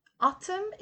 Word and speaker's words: autumn: In England, autumn is used instead of fall and the N is not pronounced. Autumn.